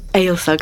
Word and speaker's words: Eyjolf: So I got down my copy of Evil Saga, is that how we pronounce it? – Eyjolf Saga, mm-hmm. I'm close enough, which I've read before.